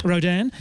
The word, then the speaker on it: Rodin: Tell us a little about how your reading of Rodin, is that the correct pronunciation? – Rodin. Yes.